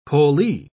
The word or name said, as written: pollee